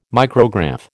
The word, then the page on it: micrograph: American English